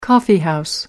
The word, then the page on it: coffeehouse: British English